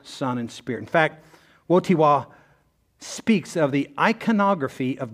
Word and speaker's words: Wojtyła: So I had to look this up, and after I looked it up and heard many people actually say his name, I still don't think I have it right, but here we go. There was a pope, John Paul II, and his name was Karol Wojtyła. I'm just telling you, that's how you say it.